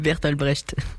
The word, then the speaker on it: Bertolt Brecht: Attendez, on me dit dans l'oreillette que ça se prononce plutôt... – C'est Bertolt Brecht.